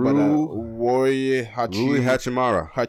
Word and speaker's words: Rui Hachimura: And I'm not sure how to pronounce his name, Rui Hachimura.